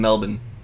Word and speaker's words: Melbourne: Otherwise known as the first Australian prisoner to step foot in Melbourne.